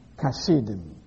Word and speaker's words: Hasidim: H-A-S-I-D-I-M. Hasidim. Some pronounce it with C-H as Kashidim.